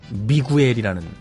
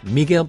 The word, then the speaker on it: Miguel: 어떻게 인제 와가지고 우리가 알고 있는 왜 그 미구엘이라는 맥주 있죠. 네, 그것도 유 발음 안 된대요. 스페인에서 미겔이라고 봐. 네. 미겔. 앞으로 이제 맥주 주문하실 때 꼭. Hello, Miguel, please.